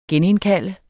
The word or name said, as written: genindkalde